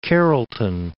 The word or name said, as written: Carrollton